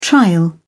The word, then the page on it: trial: British English